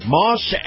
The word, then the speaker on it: Maus: In this portion of the program, my conversation with Chris Markowski, brought to you by our friends at Moss Acura. Now, Moss is spelled M-A-U-S, and if you love the feel of a high-end SUV, you're going to love Moss Acura.